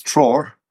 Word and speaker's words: Treoir: Um, I'm not pronouncing that right, am I? It's not bad. It's Trur, but.